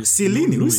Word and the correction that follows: Chiellini: Are you talking about Chiellini?